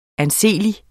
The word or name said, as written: anseelig